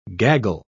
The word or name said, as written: gaggle